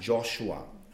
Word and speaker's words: Joshua: In manchen Übersetzungen steht Jeschua oder Josua, aber die richtige Aussprache ist Joshua.